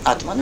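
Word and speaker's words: ātman: But the most important thing, that self-atman with long A, atman, very simple, yes, it does not think.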